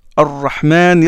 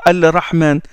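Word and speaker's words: Ar-Rahman (الرحمن): Ar-Rahman, l'Ora. Ar-Rahman, Ir-Rahim. On ne dit pas Al-Rahman.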